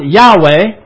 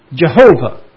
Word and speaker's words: Yahweh: Now, sometimes it's pronounced Yahweh, and sometimes it's pronounced Jehovah.